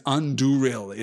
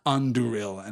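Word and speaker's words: Andúril: The little accent mark above a vowel in a Sindarin word means that that is a stressed vowel, effectively. It is Anduril. It is the stress there falls on the second of the three syllables.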